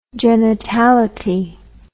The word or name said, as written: genitality